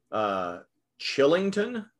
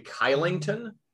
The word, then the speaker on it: Kylington: And Calgary has a player whose name looks like Chillington, but it's actually Kylington.